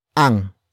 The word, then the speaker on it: ang: The next word is ang, which is spelled as A-N-G.